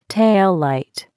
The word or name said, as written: taillight